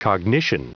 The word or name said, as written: cognition